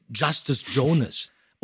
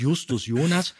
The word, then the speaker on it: Justus Jonas: und dann haben wir gesagt, okay, das wird deutsch ausgesprochen, der Name, der heißt Justus Jonas.